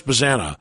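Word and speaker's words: Bazzana: We also found out, so Travis Bazana is actually how we say the name.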